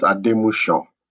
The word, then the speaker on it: Ademusu: It's pronounced Ademusu. – Ademusu. So the S and the second one has the shish.